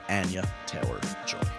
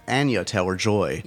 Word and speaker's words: Anya Taylor-Joy: Enjoy this conversation with Anya Taylor-Joy. Anya Taylor-Joy.